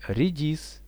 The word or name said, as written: redis